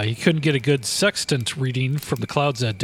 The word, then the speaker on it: sextant: T-E-T? – Like sex tant, only when the tent is a tant. – Oh, tant. – So it's like sex tant.